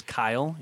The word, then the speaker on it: Keil: My name's Bryce Kyle. It's spelled K-E-I-L. It's not Keel. It's pronounced Kyle. So when you see it later, don't butcher it.